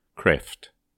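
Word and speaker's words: Cræft: Or, as I think he would prefer the title to be pronounced, Kreft. The English letter Ash is a tricky beast to pronounce.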